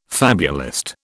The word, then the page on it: fabulist: British English